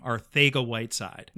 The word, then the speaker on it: Arcega-Whiteside: He was doing the broadcast, but Ross Tucker saying JJ Arcega-Whiteside. – Ooh. – It is a Spanish pronunciation, like Spaniard Spanish, and it is the King Spanish. So that is actually how JJ pronounces his last name, Ortega Whiteside.